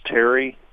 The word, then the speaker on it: Tierre: Jerry and, oh my gosh, Karen, I think it'd be pronounced Terry, T-I-E-R-R-E, Schwartz of Spring Valley, sent me a photo of a beautiful house finch male.